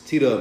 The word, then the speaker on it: T Dub: It's T Dub, man.